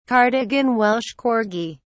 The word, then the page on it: cardigan welsh corgi: British English